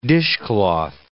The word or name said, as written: dishcloth